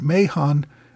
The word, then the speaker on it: Mahon: It should have been Mahon.